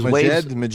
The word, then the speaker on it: Majid: Funky Mix came out with this remix of Majid. Is that how you pronounce the name? Majid Jordan. This is Majid. – M-A-J-I-D, Jordan.